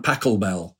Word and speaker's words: Pachelbel: And on there he did a kind of set of versions of an old canon in D major, Johann Pachelbel. I hope I pronounced that right. – You have indeed.